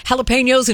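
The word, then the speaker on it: jalapeños: Whole Foods recalling dozens of items that contain jalapenos, and see, Jeff, you've taught me how to say it right, jalapenos in 12 states, including one.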